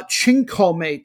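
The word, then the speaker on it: Tchinkoumé: This included a style called, I'm going to pronounce it, Chinkome, T-C-H-I-N-K-O-U-M-E with an apostrophe over it,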